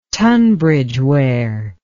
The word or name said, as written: tunbridgeware